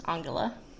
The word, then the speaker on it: Angela: they would hypothetically tell him it's pronounced Angela.